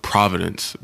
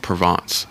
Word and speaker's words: Provence: It's crazy that you went to Paris and they have another place called Provence that sounds just I used to pronounce it Providence, but it's pronounced Provence.